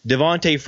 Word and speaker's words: Devonta: But I finally came to the conclusion that it is important that I pronounce Devontae Freeman's name as he pronounces it. If he wants to go by Devontae, even though it's spelled Devonta, it's my responsibility to pronounce it correctly.